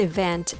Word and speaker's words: even: There's a similar word, even. This word has the stress on the first syllable. So it's even. Now the second syllable becomes weak, like ven, ven. You don't have to say ven, it's ven, even.